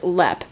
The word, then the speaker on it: Leap: So today we are hopping back across the pond to Ireland, specifically to Leap Castle, spelled L-E-A-P like leap, but pronounced Lep because Gaelic is weird.